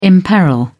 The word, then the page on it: imperil: British English